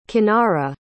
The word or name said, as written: cynara